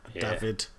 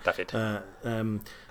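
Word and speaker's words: Dafydd: This is David or Daffid. I don't know how to pronounce that. – Daffid.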